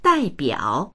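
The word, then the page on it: dai4biao3: Mandarin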